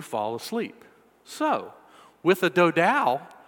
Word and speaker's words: Dodow: It is spelled D-O-D-O-W. All right, so I'm calling it the Dodow, all right?